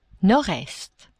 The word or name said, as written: nord-est